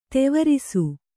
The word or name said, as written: ತೆವರಿಸು